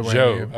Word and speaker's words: Zhou: Zhou.